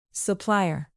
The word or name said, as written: supplier